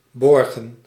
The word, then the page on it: verrouiller: /vɛ.ʁu.je/; /ve.ʁu.je/